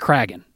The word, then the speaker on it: Kraken: And really, you've got the Kraken. Am I pronouncing that correctly? – The there's a dispute over the pronunciation. – No, it's the Kraken.